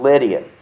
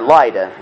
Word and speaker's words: Lydda: He came over to a little place called Lydia, or Lydda is the correct way to say that.